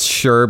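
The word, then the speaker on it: Shure: Yeah, it's Sure, but spelt without an H.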